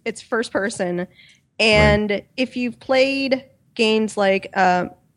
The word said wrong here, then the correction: Minerva's: Den? Is that how you pronounce it? – Minerva's. – That's it.